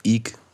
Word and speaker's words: Eek: Por si alguien quiere buscarlo y está escuchando esto simplemente, este IC se escribe EEK, por si acaso queréis buscarlo, pero es verdad que la onomatopeya es IC.